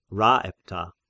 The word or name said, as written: Rhaepta